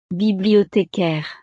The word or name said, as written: bibliothecaire